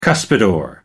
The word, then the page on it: cuspidor: American English